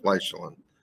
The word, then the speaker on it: Lysulin: L-Y-S-H-E-L-I-N. – I say it Lyshelin, like insulin, only with Lyshelin.